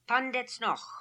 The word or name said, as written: Pandetznokh